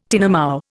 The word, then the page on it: tinamou: Indian